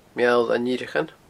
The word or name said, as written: Meall an Fhireachain